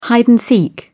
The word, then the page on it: hide and seek: British English